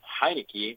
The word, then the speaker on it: Heinicke: Okay, so with the Battle Hawks, former Battle Hawks, backup QB Taylor Heinicke, is that how you say it? – Yeah.